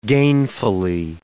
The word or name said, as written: gainfully